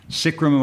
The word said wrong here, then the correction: Skyrim: am I pronouncing that right? – Oh, Skyrim.